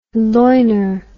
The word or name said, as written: Loiner